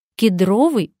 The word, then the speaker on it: кедровый: Но кедровый бальзам. В прилагательном «кедровый» запоминаем ударение на втором слоге.